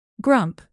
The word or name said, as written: grump